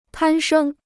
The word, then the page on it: 攀升: pān shēng